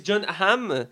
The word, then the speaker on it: John Hamm: on a aussi John Hamm dans le j'ai bien prononcé John